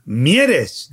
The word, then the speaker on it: Mieres: Is it Mieres? – Mieres.